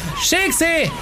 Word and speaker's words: Schilksee: Erstmal, wie spricht man den Namen aus? – Schilksee. – Korrekt.